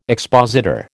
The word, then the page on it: expositor: American English